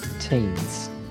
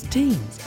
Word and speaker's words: Tignes: So how do you pronounce that place again? – Teens. – It's Teens. I wouldn't have pronounced it as Teens, but...